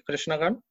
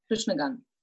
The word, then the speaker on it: Krishna Gaan: Did I say the name correctly, Krishna Gaan? – Yeah, Krishna Gaan.